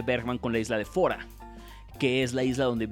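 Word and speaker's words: Fårö: es, sueco, se pronuncia Fora, se escribe ah. Faro.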